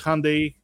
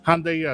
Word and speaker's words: Hyundai: You know, they decided that uh, they would say that it's Hyundai uh, in order to uh, facilitate, uh, you know, pronunciation. Hyundai as in rhyming with Sunday.